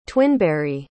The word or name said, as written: twinberry